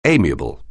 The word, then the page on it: amiable: anglais